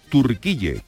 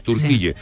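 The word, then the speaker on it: Türkiye: Entonces, los, los, eh, los eh, turcos denominan a su país Turquille, con, la U con diéresis, Turquille.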